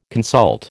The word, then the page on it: consult: American English